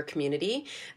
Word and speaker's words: Qmunity: Talking about this, I want to give a shout out to an organization based here in Vancouver called Community. So it's spelled Q-munity, the letter Q. They do such important work supporting queer individuals and families. And on their website, which is community.ca, Q, the letter Q, M-U-N-I-T-Y.ca, they have the most comprehensive queer terminology glossary I've ever seen.